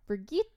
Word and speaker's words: Brigitte: It's the Swedish pronunciation of the name Bridget, but it's pronounced Brigitte.